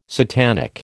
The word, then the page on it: satanic: American English